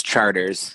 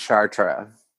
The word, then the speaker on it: Chartres: It's Charters, is how you say it. – Charters, okay. So I made it more difficult than it was. – It actually, well, in France is Chartres, and of course in New Orleans we say everything wrong, so it's Charters here.